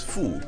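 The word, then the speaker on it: FUH: le H, qui se prononce Fou, Compa Beat Fou. Il ne s'agit nullement du fou qui s'écrit F-O-U.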